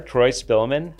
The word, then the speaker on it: Troy Spillman: And I'm joined by local global serving pastor extraordinaire, Troy Spillman.